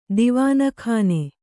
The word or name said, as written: ದಿವಾನಖಾನ